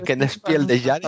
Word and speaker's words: Kennerspiel des Jahres: No sé si lo he dicho bien, doctor Mabuse. Kennerspiel des Jahres. – Bastante bien. Un alemán de la Baja Sajonia no te entendería, pero del resto de Alemania posiblemente.